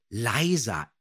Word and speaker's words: Liza: Auf dem Deckblatt hier steht hinter dem Rollennamen von Madeleine Stolze von Frau Körting handschriftlich vermerkt, Liza, englisch ausgesprochen.